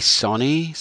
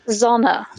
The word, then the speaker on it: Sonne: S-O-N-N-E, Sony? Sone? – Zona. Is actually a Danish artist, and I'm probably not pronouncing it right either.